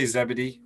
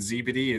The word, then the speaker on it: ZeBeDee: Yeah, I say ZeBeDee because I'm English, but it's an acronym. So like technically there's no correct way to say it. And I think like the American ZeBeDee is, we're probably going to like push towards that.